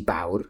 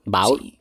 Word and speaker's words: Baur: que yo no sé si se pronuncia el nombre así, se deletrea B-A-U-R. ¿Cómo pronunciaría usted ese apellido? Yo creo que sí, Bauer. ¿Sí? – Bauer.